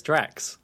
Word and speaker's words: Drax: The Drac Corporation. – It's not Drac, though. It's Drax.